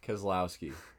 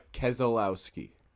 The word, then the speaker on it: Keselowski: Keselowski. Yes. – KeselowSki. – Yes. – No way. No way. No. Okay. I got help from you because you kind of pronounced the extra E.